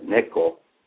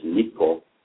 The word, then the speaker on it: neko: She has a nickname that her family gave her when she was going to move to Hawaii, cat or neko. They mispronounce it as Nico.